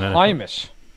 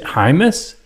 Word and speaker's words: Jaimes: So now you're getting at 94th overall in the third round, Brendan James, an offensive tackle out of Nebraska. And so when I pull up this guy's scouting report, pronounced on Hymus. – Is it Hymus? – Brendan Hymus.